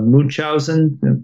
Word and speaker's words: Munchausen: And they sought after all their old favorites, like the Green Hornet, Speed Racer, a version of Ghostbusters, even movies like Baron Munchausen. Am I even saying that right? – Yeah.